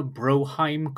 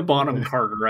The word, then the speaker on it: Bonham Carter: Helena Broheim Carter? – Broheim? – I did that one on purpose. Helena Bonham Carter, I think is the way you're actually supposed to say that.